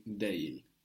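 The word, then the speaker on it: değil: Or as we mentioned in writing, deil. You would see de, the soft g with the little upside down point on the top of it, il. To take or to buy is almak. – Almak. – Almak. A-L-M-A-K.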